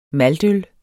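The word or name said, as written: MALTØL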